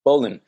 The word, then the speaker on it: Bowlin: Bowlin.